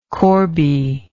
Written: corbie

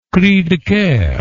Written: cri de coeur

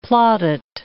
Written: plaudit

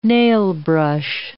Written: nailbrush

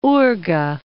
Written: Urga

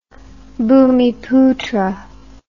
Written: bumiputra

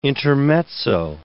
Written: intermezzo

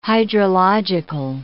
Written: hydrological

